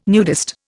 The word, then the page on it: nudist: Indian